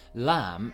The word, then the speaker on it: lamb: So lamb is spelt L-A-M-B. But you don't pronounce the B at the end, and knee is K-N-E-E, but we don't say the K at the beginning.